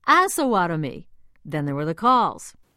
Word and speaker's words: Osawatomie: I believe it's pronounced Osawatomie. Well, Lori Ward of Lawrence, Kansas, was one of many who wrote, "It's Osawatomie."